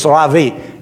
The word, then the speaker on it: Ravi: Use the correct Hebrew pronunciation and not the way they write it in the Bible. It's Ravi, and it means my rabbi, my teacher.